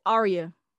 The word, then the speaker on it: Aria: Aria.